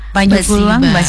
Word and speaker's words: Basiba: Itu pengucapannya baju kurung Basiba. – Kalau bahasa Minangnya.